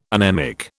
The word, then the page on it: anemic: British English